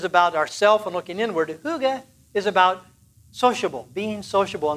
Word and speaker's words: hygge: And the word is hygge. And there's lots of different ways to pronounce it. H-Y-G-G-E, hygge, hygge.